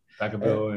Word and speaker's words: Vacaville: So, you're a Californian boy and attended, might say this wrong, Vacaville CA Wood High School, is that correct? – Yeah, Vacaville and mostly Wood High School, yes.